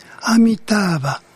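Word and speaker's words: Amitābha: で、阿弥陀仏、阿弥陀ってのは何から来てるかっていうと、古代のサンスクリット語ってのはインドの、聖なる言葉とも言うんですが、そのサンスクリットで発音するところでは、アミターバ、あるいはそのアミタイウスというふうに呼んでいたらしい。で、これが阿弥陀を、漢字に、一字一音で、簡訳されたわけですね。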